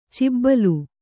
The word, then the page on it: ಚೌಬಲ: caubala